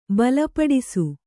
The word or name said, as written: ಬಲಪಡಿಸು